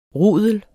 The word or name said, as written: rudel